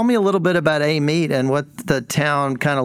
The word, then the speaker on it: Amite: Amite. You said it correctly.